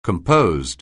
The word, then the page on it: composed: anglais